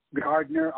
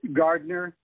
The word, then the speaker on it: Gardner: I would direct them to, if they just search Psalmist Pete Gardner on Facebook, that would get them there. I also have a website that's Pete Gardner.